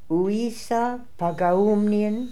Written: Ooissapagaoomnian

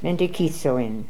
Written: Mentekithzoin

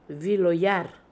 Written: Viloyer

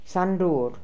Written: sandoor

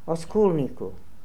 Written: Oskolnikoo

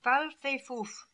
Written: Fal Fe Foof

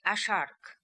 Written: Ashhark

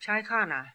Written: Chaihana

